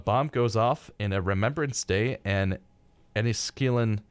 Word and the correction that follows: Enniskillen: sorry, I've got to say it right, Enniskillen, Northern Ireland. That was Scottish. I didn't mean to do that.